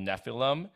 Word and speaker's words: Nephilim: And it mostly focusing on a warring faction of half angels called Nephilim and divergent rogue angels called Grigori.